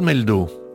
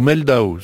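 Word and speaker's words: Mehldau: » Exactement de la même génération, puisque né en 1970 lui aussi, Brad Meldow, ou Meldau selon sa prononciation à lui, probablement le pianiste le plus influent des dernières décennies.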